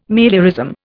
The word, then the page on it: meliorism: Indian